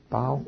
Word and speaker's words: bough: Did I say that right? Bow? Okay, good, good. I get confused with that.